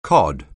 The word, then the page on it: cod: anglais